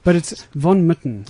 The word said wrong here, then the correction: Von Mertens: Is that correct? – Close, yeah. Von Mertens. – Von Mertens, I beg your pardon.